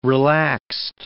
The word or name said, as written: relaxed